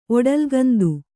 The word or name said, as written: ಒಡಲ್ಗಂದು